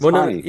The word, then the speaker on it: Hani: I noticed that it's Hani. I'm like, why they keep calling him Ronnie? It's Hani.